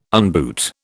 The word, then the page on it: unboot: British English